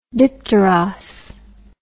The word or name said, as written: dipteros